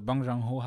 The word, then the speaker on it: Bong Joon-ho: Also Bong Joon-ho habe ich, glaube ich, richtig Bong gesagt. Bong Joon-ho, glaube ich. Ich glaube, das zweite Dings machst du mit ein bisschen ein U.